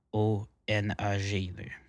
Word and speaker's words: Wampanoag: Moi, excuse-moi pour avoir mal prononcé ça, c'est aucunement moi en train de manquer de respect pour les tribus autochtones, mais c'est Wampanoag.